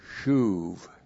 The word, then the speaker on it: shuv: And then the verb shuv. S-H long U-B, shuv.